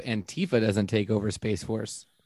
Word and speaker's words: Antifa: I pronounce it Antifa, like aunt, like she's your auntie.